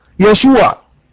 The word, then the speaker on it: يشوع: النقطه رقم 2، النقطه رقم 2، قلنا ان يسوع في العبريه تنطق يشوع.